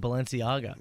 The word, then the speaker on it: Balenciaga: you major props for pronouncing Balenciaga yes, correctly.